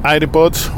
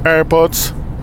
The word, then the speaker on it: AirPods: AirPods sería. Bueno, yo le digo AirPods. Mi señora me reta porque dice, no, me está pronunciando mal. No, bueno, yo lo pronuncio en castellano. AirPod. Pero igual, bueno, me reta, ¿no? Tenés que hacerlo como son en inglés, tienen razón.